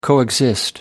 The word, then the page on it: coexist: British English